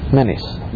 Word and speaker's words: Menzies: Oh, we leave out the Z? And he pronounces it... Menis. Mengus. Okay.